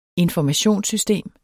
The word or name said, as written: informationssystem